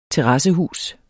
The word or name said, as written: terrassehus